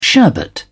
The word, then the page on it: sherbet: British English